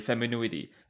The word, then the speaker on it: Feminuity: And to that, so like that kind of brings us to your company, Femenuity. Am I saying that right? – Yeah, you got it.